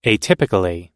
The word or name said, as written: atypically